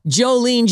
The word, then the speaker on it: Jolene: But it's a latte that's on the lean side, L-E-A-N. But it's spelled Jolene, J-O-L-E-N-E, like Jolene, Jolene, right.